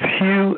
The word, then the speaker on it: Hugh: It's Hugh, H-U-G-H, last name Charlson. That's spelled T like Tom, R-A, U-L-S like Sam, E like Edward, N like Nancy, and then just put Twitter after my name.